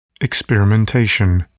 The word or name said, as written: experimentation